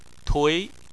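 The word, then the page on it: tui: tone 2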